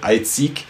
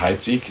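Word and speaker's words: Heidsieck: Viele Häuser haben deutsch klingende Namen, auch wenn die im Französischen ausgesprochen, wenn man das nicht so hört, Eizig oder Tétinger, aber Teitinger und Heizig sind ganz klar deutsche Namen und die Teitinger, glaube ich, österreichisch, muss ich mich korrigieren.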